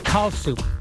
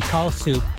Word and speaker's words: Kallsup: It's Kalsup. K-A-L-L-S-U-P, Kalsup.